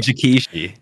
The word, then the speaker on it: Jikishi: Jikishi.